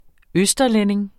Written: østerlænding

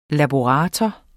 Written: laborator

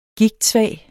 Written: gigtsvag

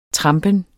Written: trampen

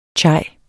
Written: chai

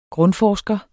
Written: grundforsker

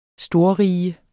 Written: storrige

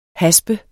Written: haspe

